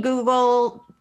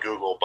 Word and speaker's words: googol: And so the number was called Google, or I think he just said Google, but it's spelled different. It's spelled G-O-O-G-O-L, Google and Googleplex.